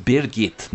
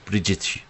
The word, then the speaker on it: Birgit: She says, "It is pronounced Birgit, not Bridget, Mr. Dives. That is the German way."